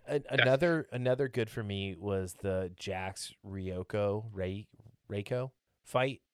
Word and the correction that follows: Reiko: Reiko, yeah.